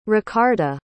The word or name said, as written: Ricarda